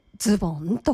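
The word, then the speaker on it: 洋袴: はい、ズボンは漢字だと洋風の袴と書いてズボンと読むということです。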